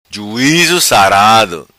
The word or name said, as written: Sarado Juizo